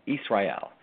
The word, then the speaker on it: Israel: Israel is the actual way to pronounce it, Hebrew way.